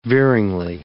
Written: veeringly

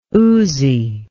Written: uzi

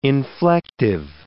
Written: inflective

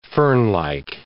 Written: fernlike

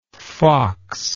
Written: foxlike

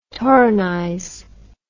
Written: torrenize

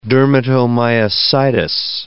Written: dermatomyositis

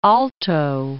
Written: Trujillo Alto

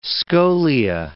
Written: scholia

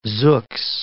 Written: zooks

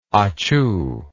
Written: achoo

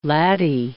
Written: laddie

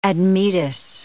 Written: Admetus

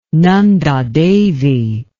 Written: Nanda Devi

